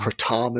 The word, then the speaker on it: Kratom: Kratom is how it's actually apparently pronounced, is what Hamilton says on Hamilton's Pharmacopoeia on Vice.